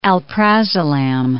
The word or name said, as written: alprazolam